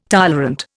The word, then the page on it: tolerant: Indian